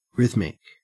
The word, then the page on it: rhythmic: Australian English